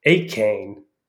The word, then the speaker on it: Achane: I looked it up on the internet, and the internet says it's Achane is how you would pronounce it.